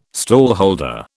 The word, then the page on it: stallholder: British English